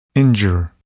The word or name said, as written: injure